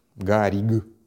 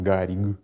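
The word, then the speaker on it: Garrigue: А, создателем Первой Республики Чехословакия был такой политический деятель Томаш Гарик Масарик. Гариг Масарик. Я его как Гарик, конечно, произношу, но он Гариг Масарик.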